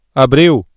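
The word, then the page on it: abril: Brazilian Portuguese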